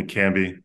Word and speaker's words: Kambi: My full name's Adekambi Laleye, but everyone calls me Kambi.